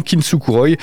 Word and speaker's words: Kintsukuroi: Ils viennent de sortir le troisième volume de leur EP qui s'appelle, donc de leur série de EP qui s'appelle Kintsukuroi. Oui, je le dis bien, Kintsukuroi.